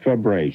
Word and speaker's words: Fabares: And she pronounces it Fabre, Shelley Fabre.